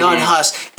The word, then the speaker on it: Jan Hus: It would have informed individuals like John Smith, Smurf, Jan Hus. There you go. Make sure I got his name right. Yeah. Jan Hus.